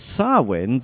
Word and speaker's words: Samhain: It is spelled S-A-M-H-A-I-N, pronounced Samhain.